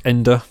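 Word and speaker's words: Ende: Michael End. – Correct. I think it's pronounced Ender, but I'll let you have it. – Ender. Question three. – Pronounced Michael Ender if he's German, but anyway, yes, correct.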